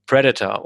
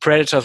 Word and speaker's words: Predator: Oder wie Kinder, wie ich es damals waren, nicht Predator, sondern Predator gesagt haben. Man hat es damals mal falsch ausgesprochen in Deutschland. Predator, mal gesagt. Aber er heißt tatsächlich Predator, das Haupttier. Ja, also mal Predator, ne, weil das immer die Silbe, die ersten Silben falsch betont.